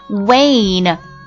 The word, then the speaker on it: wane: 第二个单词的话呢，也是啊，跟这个第一个 one 它的写法非常相似，只不过在这个 one 的基础上加了一个字母 e，读音变了，读作 wane。来，在脑袋里面记一下这个单词，W-A-N-E，这个 A 就发作 A 音，Wane，什么意思呢？